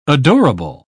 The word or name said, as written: adorable